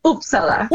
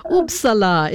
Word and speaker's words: Uppsala: So you live in Uppsala, that's how it's pronounced, yeah? – Uppsala. – Okay, oops from me. Yeah. Uppsala.